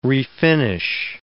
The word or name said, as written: refinish